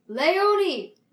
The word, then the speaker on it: Leonie: Her name is Leonie. You said Lee. You're saying oh. – Lee. – Oh, sorry. – Lay. – Lay chips. – Lay chips. – Lays. – I was trying to say it like Leo. Because it's spelled L-E-O-N-I-E. – Yeah, but Leonie. – Leonie. – Leonie. Leonie! Her name is pronounced Leonie.